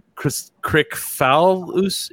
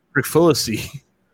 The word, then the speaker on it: Kricfalusi: Krikfalusi. It's KRICFALUSI. – Okay. – Krikfalusi.